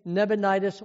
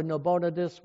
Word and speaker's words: Nabonidus: And after him came Nebonidus or Nabonidus, whichever you pronounce.